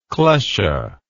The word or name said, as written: klesha